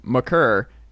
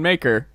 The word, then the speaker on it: Maker: Because it came out that Thon McCurr is pronounced McCurr, and people were still like, Thon Maker.